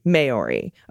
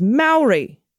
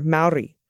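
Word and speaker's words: Māori: When I first came to New Zealand over 10 years ago, I too pronounced Maori, Maori, a common mistake, and one any Kiwi will quickly correct you on. The proper pronunciation is Maori, slightly rolling the R, Maori, like that?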